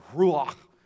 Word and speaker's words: ruach: It's called ruach in the Old Testament. You have to get that little popcorn in the back of your throat to say that right.